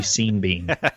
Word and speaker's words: Sean Bean: Also, Sean Bean's name should be pronounced the same, the first and last, so it should be Sean Bean.